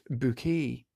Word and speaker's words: Bucket: Ordinarily known as Mrs. Hyacinth Bucket, she continually insists that her surname is enunciated with extra gravitas and flourish on the syllables of that surname, revealing it often as pronounced bouquet.